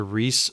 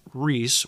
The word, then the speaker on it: Rhys: And it is the anglicized form of Rees, which is spelled R-H-Y-S, which I guess you would say that is Rees or Rice. Anyways, R-H-Y-S was the name of the last ruler of an independent kingdom of Wales.